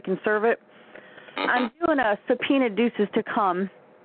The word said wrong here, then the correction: tecum: I think tecum.